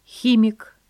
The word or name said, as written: химик